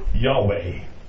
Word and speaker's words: Yahweh: In the Hebrew language, there is no letter J. You would pronounce it with a Y. Yahweh, the name of God.